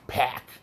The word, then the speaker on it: Pac: Pack. The Pack. Gotta say it right.